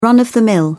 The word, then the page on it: run of the mill: British English